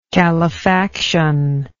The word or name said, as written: calefactive